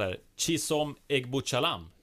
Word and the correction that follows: Egbuchulam: Egb... Eller? Egbuchulam. – Ja, Egbuchulam.